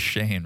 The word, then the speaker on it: Shaney: Grandma Cheney's here. – Reverend Grams is arriving. – You know her name is Shane, right? – It's not Cheney? – No, it's spelled as Shaney, but it's Shane.